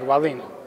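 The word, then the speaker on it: Wallin: I'm doing great, and you said it right, Valine.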